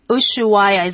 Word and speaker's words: Ushuaia: Okay, 好 cool, interesting, 会下雪的夏天，这可能是我们这住在台湾很难理解的一个地方哦。Okay, 那我们来看一下刚刚提到有个地方就是 Ushuaia, is that how you say it? – That is right, yeah.